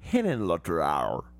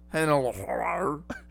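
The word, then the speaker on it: Henenlotter: It's pronounced Hennenlotter. – Nice. – Is that really how it's pronounced? – Yes, Hennenlotter. – Hennenlotter.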